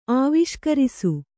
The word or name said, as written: ಆವಿಷ್ಕರಿಸು